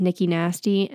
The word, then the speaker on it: Nikki Nasty: And then my TikTok is Nikki Nasty, N I K K I N A S T Y kind of rhymes, whatever.